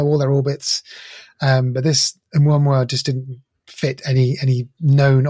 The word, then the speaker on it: Oumuamua: I think that's the correct way of saying it. Oumuamua. It's a Hawaiian word and it means scout.